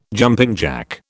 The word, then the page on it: jumping jack: British English